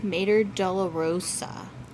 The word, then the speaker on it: Mater Dolorosa: How do you say the name of the cemetery? – Mater Dolorosa.